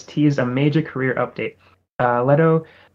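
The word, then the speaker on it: Lehto: Marcus Leto, am I saying that right? Leto? – Marcus Leto, okay. Yep.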